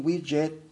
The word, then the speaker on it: widget: W-I-D-G-E-T, widget, se pronunciaría, pues ustedes hispanoparlantes, un widget o widget, como lo quieran pronunciar, pero la pronunciación correcta es widget,